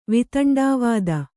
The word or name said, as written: ವೈತಂಡವಾದ